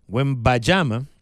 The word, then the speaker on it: Wembanyama: Desde el momento en que al minuto 11 el alien Wembanyama, siempre se me olvida cómo se pronuncia, Wembanyama, el alien francés, al minuto 11 saliera por haberle propinado un codazo.